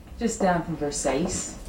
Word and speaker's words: Versace: Thanks. It's a Versace. – It's Versace. – What? – It's pronounced Versace.